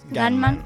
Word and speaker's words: Gunman: Primera semana en taquilla de The Goodman, ¿se dice así bien? Gunman. Ah, perdona.